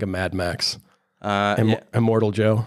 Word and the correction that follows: Immortan Joe: Immortan Joe, The gangs, whatever pronunciation they have.